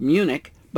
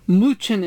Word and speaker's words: München: or commonly called Munich, but München is the local pronunciation.